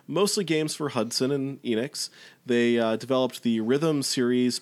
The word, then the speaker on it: Enix: My understanding is it's Enix like Phoenix. – Okay, that would make sense. Yeah, so we got, so this comes to us from Enix and developer Produce, which I've seen spelled with an exclamation point, so I'll just keep saying it.